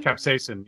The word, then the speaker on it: Capsaicin: But again, when you're dealing with jalapeno, how do you pronounce it? Capsaicin? – Capsaicin, yeah.